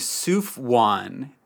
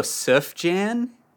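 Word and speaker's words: Sufjan: And so Stevie goes, yeah, there's this cool guy named Souf Wan. This is my introduction is we called him Souf Wan Stevens. Which, looking back, is so funny to me because normally the pronunciation, people go Souf Jan.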